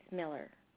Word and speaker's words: Millar: So that's why I usually say when I'm spelling it's Millar, but it's Miller.